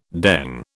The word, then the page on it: dengue: British English